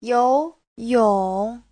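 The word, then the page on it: youyong: you2yong3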